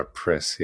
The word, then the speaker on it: Pris: So I always have found, Pris or Pre, whatever the Pre. – I call her Pris. Yes. Pris. – That's how it's spelled.